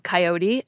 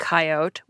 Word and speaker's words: coyote: There are actually more than two different ways to pronounce it. You have coyote and coyote, which you heard in the clips. But then some people pronounce it with a little bit of a different ending, coyote.